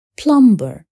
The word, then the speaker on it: plumber: مثل كلمة plumber التي تحتوي أيضا على حرف B الصامد. هناك قاعدة أخرى للحرف B وإذا وجدت قبل حرف T صمتت أيضا مثل داو والدب والصرل.